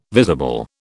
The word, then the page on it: visible: British English